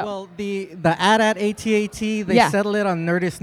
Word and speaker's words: AT-AT: They get promos, and they get press releases from Star Wars, and it's AT-AT. – Yeah! – Oh, snap! – Yeah, as in rhymes with hat is the way the press release says.